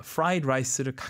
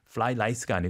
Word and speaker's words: fried rice: 여기서 발음 fried rice를 가끔 이제 좀 이렇게 혀가 꼬일 수가 있어요. F 발음, R 발음. 플라이 라이스가 아니고 fried rice.